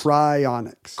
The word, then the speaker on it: cryonics: And then I said cyrogenics, and then I said, oh, wait, you're saying it's chirogenics. And you said, no, it's not chirogenics. It's chironics? – Cryonics. – Cryonics. – Yeah. – Even when I know that I am pronouncing it wrong and try to pronounce it right, I'm still pronouncing it wrong.